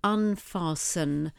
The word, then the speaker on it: unfasten: And the opposite for this will be to loosen or to unfasten. And that's spelled U-N-F-A-S-T, silent T-E-N.